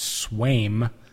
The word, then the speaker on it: Sveum: Central Standard Time, Theo Epstein, Jed Hoyer, and the rest of the Chicago Cubs front office will be introducing Dale Sweam, that's spelled S-V-E-U-M, pronounced Sweam, the current Milwaukee Brewers hitting coach, as the next manager of the Chicago Cubs.